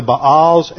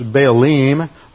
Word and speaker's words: Baalim: In the Hebrew it's Baalim or Baalim. That's really the correct Hebrew pronunciation. Most English speakers just pronounce it Baal. But it's the Baalim in Hebrew.